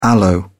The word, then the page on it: aloe: British English